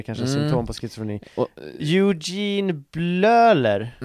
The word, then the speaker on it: Bleuler: Bleuler. – Eugene Bleuler. – Bleuler.